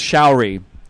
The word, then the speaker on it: Shauri: Also, that track was called, I'm going to say that's pronounced Shauri, S-H-A-U-R-I, Lincoln Six Echo.